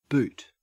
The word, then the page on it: boot: Australian English